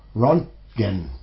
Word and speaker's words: Röntgen: Start off with Röntgen by Krokel. – Yeah. – I pronounce it okay? – Yeah, that's like okay. Named after Röntgen, like an X-ray.